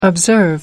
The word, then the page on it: observe: American English